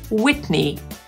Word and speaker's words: Whitney: Jeśli ich pisownia kończy się na EY, w wymowie zakończą się na I. Na przykład znana piosenkarka Whitney Houston.